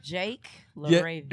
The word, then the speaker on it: LaRavia: Jake Laravia.